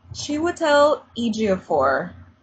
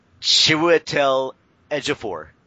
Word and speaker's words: Chiwetel Ejiofor: Chiwetel Ejiofor, I think.